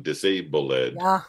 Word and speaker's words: disabled: And the interesting thing is then the next line where he says limping sway, and then you have to pronounce the ED on disabled, which sounds really limping to me.